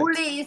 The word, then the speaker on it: Ulli: Ulli is my first name.